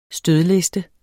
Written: stødliste

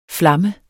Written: flammet

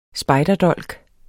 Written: spejderdolk

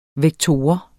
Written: vektor

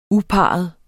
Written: uparret

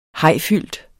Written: hajfyldt

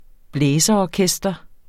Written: blæserorkester

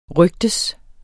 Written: rygtes

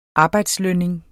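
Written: arbejdslønning

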